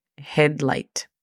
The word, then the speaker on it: headlight: La pronunciación fonética es J-E-D-L-A-I-T, headlight.